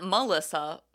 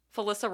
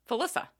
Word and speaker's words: Felissa: First, as mentioned, lead role, Angela, played by, I'm going to say, so it's not Melissa, Felissa Rose. – Okay. – Yeah, with an F, not an M. It's spelled the same way as Melissa, but with an F instead of an M.